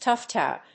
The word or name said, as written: toughed out